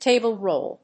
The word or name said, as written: table roll